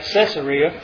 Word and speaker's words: Caesarea: I know everybody pronounces that Caesarea, but it's named after Caesar, not Caesar.